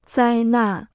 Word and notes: 灾难: zāi nàn

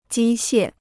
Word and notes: 机械: jī xiè

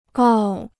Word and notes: 告: gào

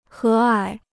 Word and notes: 和蔼: hé ǎi

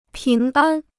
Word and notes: 平安: píng ān

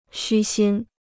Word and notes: 虚心: xū xīn

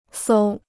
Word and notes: 搜: sōu

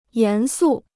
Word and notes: 严肃: yán sù

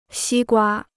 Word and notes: 西瓜: xī guā

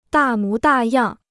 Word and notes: 大模大样: dà mú dà yàng

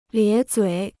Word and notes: 咧嘴: liě zuǐ

